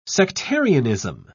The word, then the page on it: sectarianism: British English